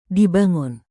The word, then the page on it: dibangun: indonesian